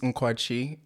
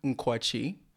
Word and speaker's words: Nkwachi: My name is Nkwachi, or just NK for short.